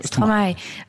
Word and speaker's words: Stromae: On prononce Stromae ou Stromae. – Stromae.